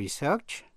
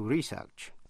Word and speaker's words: research: Động từ to research có 2 cách đọc. To research hay là to research. Một chữ nữa là transfer. Transfer nghĩa là chuyển. T-R-A-N-S-F-E-R.